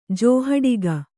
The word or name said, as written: ಜೋಹಡಿಗ